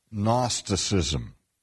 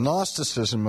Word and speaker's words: Gnosticism: and that was the movement called Gnosticism. Now, when we spell Gnosticism, we spell it with a G, G-N-O-S-T-I-C-I-S-M. And Gnosticism comes from the Greek word Gnosis, which is also spelled with a silent G, G-N-O-S-I-S, which is simply the Greek word for knowledge.